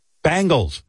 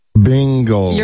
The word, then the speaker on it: Bengals: Will the country please stop telling us how to pronounce our own team? Bangles is how Cincy pronounces it. Deal Bangles. With it. – Bingles.